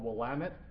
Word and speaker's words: Willamette: It's called Willamette. That's how we pronounce it.